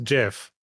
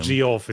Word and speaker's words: Geoffrey: Like, there's like historical figures called like Geoffrey of Monmouth, where it's spelled G E O FF, but it was always pronounced Jeff. Stop saying G off. It's weird.